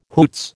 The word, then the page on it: hoots: American English